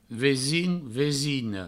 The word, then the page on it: vesin: vesina; vesino